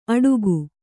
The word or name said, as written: ಅಟೂಕು